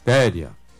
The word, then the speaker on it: therian: Me diga aí, Téria, como é que se escreve Téria? T-H-E-R-I-A-N. Téria. Tô vendo aqui agora. Abri aqui o computador. Né? Pessoa Téria. T-H-E-R-R-I-A-N.